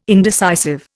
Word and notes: indecisive: Indian

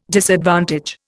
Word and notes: disadvantage: Indian

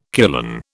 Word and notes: kulan: British English

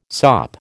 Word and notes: sop: American English

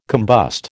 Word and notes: combust: American English

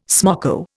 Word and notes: smoko: Indian

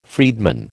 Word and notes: freedman: American English

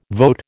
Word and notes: vote: American English

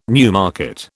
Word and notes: Newmarket: British English

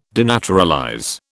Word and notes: denaturalize: British English

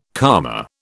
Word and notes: karma: British English

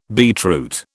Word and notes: beetroot: British English

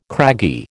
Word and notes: craggy: American English

